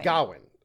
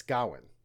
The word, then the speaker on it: Gawain: And you put the emphasis on the first syllable. And so it's Gawain. Gawain. It doesn't rhyme with Dwayne. It's Gawain, right? – Gawain, right.